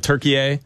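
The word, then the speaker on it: Türkiye: But also when you read on the graphics, different channels are going with turkey A.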